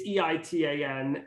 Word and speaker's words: Eitan: Eitan. You can search on all platforms and follow me. Eitan, you might be like Ayton, Aytan. It's Eitan. And does that make sense?